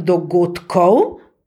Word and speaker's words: dogodkov: Ko pa rečem dogodkov, pa izgovorim T, napišem pa seveda D, ker moram izhajati iz osnovne besede.